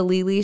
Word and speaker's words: Lili: So you can guys can hit me up on Instagram at The Lili Show. Lili is spelled L-I-L-I.